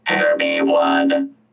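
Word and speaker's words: Hermione: How is it pronounced, Hair Me One? – Hair Me One. – Hair Me One, okay.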